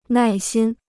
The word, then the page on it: 耐心: nài xīn